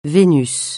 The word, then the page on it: Vénus: francais